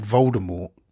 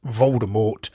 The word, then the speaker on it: Voldemort: Apparently, J.K. Rowling wanted Voldemort to have a silent T at the end, but it wasn't until the audiobooks over in the US pronounced the T, and then by the time the films were being produced, everyone was pronouncing him in as Voldemort and not Voldemort.